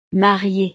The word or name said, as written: mariée